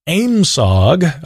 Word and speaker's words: AMSOG: Again, the DOD had already established its AMSOG, apparently that's how we're supposed to pronounce that,